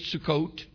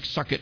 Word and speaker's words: Sukkot: Say Sukkot. It's spelled S-U-K-K-O-T, and it sounds like suck it when you say it, but when you look at it. But that's not how it's pronounced. Pronounced Sukkot.